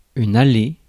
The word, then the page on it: aisle: [aɪl]; /aɪ̯l/